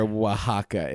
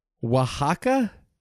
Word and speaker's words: Oaxaca: Oh, I don't know where Oaxaca is. Oaxaca? – Yeah. It's the one that's spelled O-A-X-A-C-A.